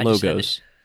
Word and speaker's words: Logos: So in Logos, did it not come with the CSB? Because Rick just got Logos, y'all. not logos. Logos. I just had it. That's how the Greek word is actually pronounced.